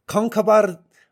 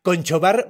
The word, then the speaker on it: Conchobar: El rey se llama Concabar Magnesa. – Órale. – Ok, y, otra vez lo voy a decir con la, con la pronunciación celta, porque si lo lees literal es Conchobar Magnesa.